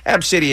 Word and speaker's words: ABCDE: And people, Ab City, if you weren't listening, her name is ABCDE. That's how it's spelled.